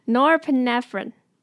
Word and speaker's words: norepinephrine: Norepinephrine, norepinephrine, 我们把它拆开来念, norepinephrine, norepinephrine, 好, 现在把它合在一起, norepinephrine, 好, 这样子大家多练习, 就会了, 知道吗?